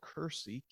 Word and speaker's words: Kursi: and it's called Kursi, K-U-R-S-I, and it's a K instead of a G because the Arabs on this side can't pronounce the letter G, so it's almost like Gersi, Gerasene.